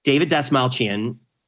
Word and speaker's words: David Dastmalchian: I'm really excited today to talk to this gentleman here, David Dastmalchian. Okay, that's how you pronounce his name.